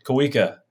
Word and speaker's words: Kauwika: You got my middle name right. That's good on you, man. Kawuka. Some people pronounce it all kinds of weird.